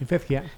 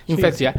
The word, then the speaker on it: Infekcja: ¿Lo he dicho bien? – Más o menos, Infekcja. – Infekcja, sí. desde Polonia.